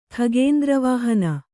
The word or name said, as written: ಖಗೇಂದ್ರವಾಹನ